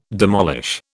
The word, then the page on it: demolish: British English